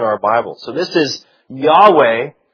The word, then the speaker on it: Yahweh: For a long time, it was pronounced "Jehovah" in English, and you still hear "Jehovah" at times. That was really sort of a strangely contrived pronunciation. Now, the best guess is Yahweh. Yahweh is maybe how it was pronounced, but Yahweh is probably the most proper way to say that.